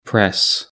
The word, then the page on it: press: British English